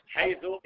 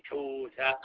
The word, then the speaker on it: حيث: حيث يقول شيخنا الشيخ ابن عثيمين لا ينبغي أن يغلط فيها أحد، ينطقها كما يريد، حيث، حيث، حيث، حوث، حيث، حيث، مهما نطقها فهو مصيب، لأن فيها تسعة لغات.